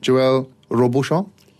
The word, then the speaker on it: Joël Robuchon: Is it Joël Robuchon? – Yeah.